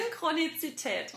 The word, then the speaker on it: Synchronizität: Synchronie Synchronizität. Ja, richtig. Synchronizität.